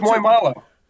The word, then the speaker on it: muy malo: What's muy malo? Wait. Answer the question. – Very bad. – Very bad. And am I saying it right? Muy malo? – Yeah.